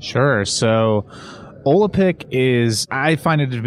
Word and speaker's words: Olapic: I'm may mispronounce it. Olapic? Olapic? – Olapic. Yeah. Olapic.